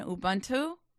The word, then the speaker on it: Ubuntu: Ubuntu? Am I saying it right? – Saying it right.